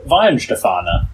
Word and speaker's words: Weihenstephaner: Did I pronounce that correctly? – I'll give you that. Weihenstephaner. Yeah. – Weihenstephaner. Okay. – My hamster is gestorben. – There you go. – Wait, that's something else. – I was missing the shush, basically, in my pronunciations.